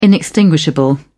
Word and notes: inextinguishable: British English

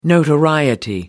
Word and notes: notoriety: American English

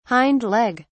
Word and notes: hindleg: British English

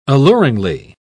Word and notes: alluringly: British English

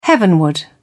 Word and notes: heavenward: British English

